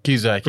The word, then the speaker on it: Cusack: Cusack? – Cusack, for Cartoon Network's nighttime programming Adult Swim.